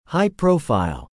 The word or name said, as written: high-profile